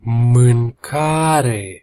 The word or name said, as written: mancare